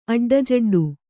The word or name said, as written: ಅಡ್ಡಜಡ್ಡು